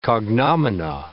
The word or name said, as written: cognomina